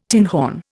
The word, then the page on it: tinhorn: Indian